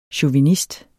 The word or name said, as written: chauvinist